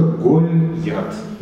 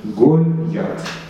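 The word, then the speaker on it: Гольят (גָּלְיָת): Можно предположить, и правильно это будет предположение, что это Гольят. Гольят. Два слога. Первый слог закрытый, безударный.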